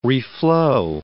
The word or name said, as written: reflow